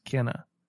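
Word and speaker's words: Kena: the verbiage police here, but I think it's actually pronounced Kenna.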